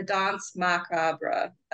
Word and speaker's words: Danse Macabre: Uh, Peter and the Wolf and the Danse Macabre. That's how you say it.